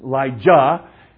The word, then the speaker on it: Elijah: But remember, Elijah never, you know, Elijah, okay, make sure I'm pronouncing that right, with the J, all right, not the S-H, but Elijah, he never really died.